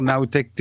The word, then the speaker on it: NowTech: Il y a pas mal de gens qui m'ont dit ah on cherchait ta chaîne YouTube en écoutant, mais comme tu prononces NaoTech, nous on a cherché Nao N A O. Non, c'est Nao en anglais N O W T E C H TV.